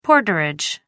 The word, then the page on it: porterage: American English